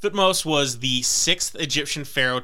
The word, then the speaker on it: Thutmose: Some people, particularly British, pronounce his name Thutmosis, but it seems like a lot of modern anthropologists pronounce it Thutmose, so I'm going to go with Thutmose.